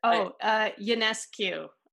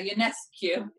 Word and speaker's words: Ionescu: I can't even pronounce it. – Ionescu. – Yeah, Ionescu.